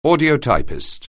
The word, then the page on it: audiotypist: anglais